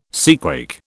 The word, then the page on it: seaquake: British English